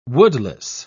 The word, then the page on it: woodless: British English